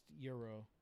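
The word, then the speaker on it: gyros: But the singular always ends in the S and is pronounced euro.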